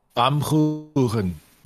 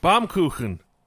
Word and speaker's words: Baumkuchen: Baumkuchen. Baumkuchen.